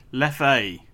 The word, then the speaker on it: Leffe: I'd never known if it's Leff or Leffe. – Leffe. – Is it Leffe? – Yeah, Belgian beer. – Yeah, it's honestly... it's. – Leff, I call it Leffe.